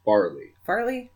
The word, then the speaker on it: Farley: Is it Farley or Fairley? – I believe it's Farley.